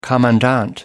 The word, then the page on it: commandant: American English